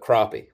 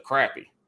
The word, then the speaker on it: crappie: So the it's a it's a long A. It's the long day. It's the crappie.